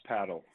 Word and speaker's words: Padel: And how do you say the name of the facility? – It's called Smash Padel. – Smash Padel, but it's P-A-D-E-L instead of P-A-D-D-L-E.